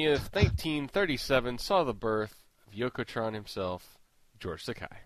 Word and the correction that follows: Takei: It's Takei. It's rhymes with gay, so that's what it's awesome.